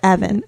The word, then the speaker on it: Avent: How do you pronounce it? I don't even know. Avent? – No, Avent. – Oh, Avent. – Avent, okay. Yeah.